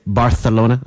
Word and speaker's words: Barcelona: Top international: Rome, Vancouver, Paris, London, Athens, Dublin, Barcelona, or should I say Barcelona? That's how they pronounce it.